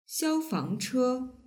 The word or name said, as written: xiaofangche